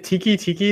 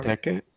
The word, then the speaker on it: Teke Teke: All right, is it Tiki Tiki? Is that how you say it? Or is that completely wrong? – Teke Teke. Teke Teke. Okay, I actually first heard of this on Sir Spooks, his video.